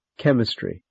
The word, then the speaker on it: chemistry: In these words, CH is k. Character, chemistry, choir, chorus, stomach, ache, anchor, architect, mechanic, orchestra, psychologist, technical.